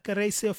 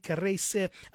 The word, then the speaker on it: Kareyce: So check out that one by Kiresi Fotso from Cameroon. It's her name is spelled K-A-R-E-Y-C-E, Kiresi Fotso. Surname is F-O-T-S-O.